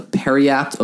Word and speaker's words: periapt: So, cool thing I got over the break was this thing called a periapt of wound closure. I have no idea if I'm saying that right. – No, you got it. – That's it? – Yep. – All right, cool.